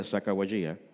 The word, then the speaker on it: Sacagawea: Sacagawea, what did they have common with the three wise men? – What? – Well, we found three things that they had in common. Both groups, Lewis, Clark, and Sacagawea, and the wise men, ventured out to discover something great.